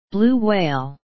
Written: blue whale

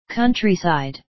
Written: countryside

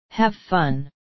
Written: have fun

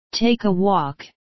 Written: take a walk

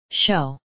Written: show

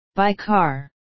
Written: by car

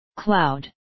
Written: cloud

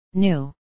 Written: new

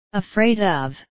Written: afraid of

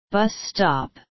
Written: bus stop

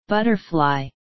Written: butterfly

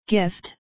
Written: gift

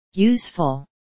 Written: useful

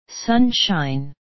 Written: sunshine

